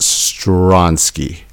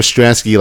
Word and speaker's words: Stransky: It's spelled S T R A N S K Y, but it's pronounced Stronsky. – That is correct. – Okay, so everyone that's been calling her Stransky, like myself, for the last year and a half, we officially we know her voice.